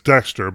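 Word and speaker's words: DXTER: I think it's called, all right, so I'm going to pronounce it as Dexter, but it's D-X-T-E-R.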